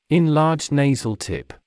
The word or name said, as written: enlarged nasal tip